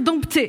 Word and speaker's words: dompter: Finalement, est-ce que c'est plouc de dire dompter? Pas du tout. D'ailleurs, la plupart des dictionnaires notent la variante de prononciation avec P ou sans P.